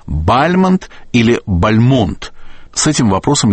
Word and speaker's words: Бальмонт: Фамилию поэта, конечно, нужно произносить с ударением на втором слоге – Бальмонт. Так он всегда произносил себя сам, так зафиксировано во многих воспоминаниях современников, так произносили его близкие, его дочь Нина Константиновна так произносила.